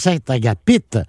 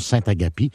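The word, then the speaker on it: Saint-Agapit: Il y a beaucoup de gens qui disent Saint-Agapite, mais apparemment qu'on prononce Saint-Agapie.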